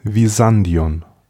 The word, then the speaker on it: Byzantion: wurde auf der europäischen Seite durch Megara die Kolonie Byzantion gegründet, oder modern ausgesprochen Visandion der Legende nach wurde der Ort nach dem mythischen Heerführer Byzas benannt.